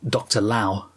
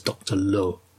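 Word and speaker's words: Lao: Some people say Dr. Lau, but I believe the correct pronunciation is Dr. Low.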